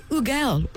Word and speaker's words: Hugel: So his name is spelled H-U-G-E-L, and he has accepted that every different country calls it a different way. So you can call him Ugel, or as he has said, the English call him Hugel.